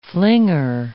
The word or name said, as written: flinger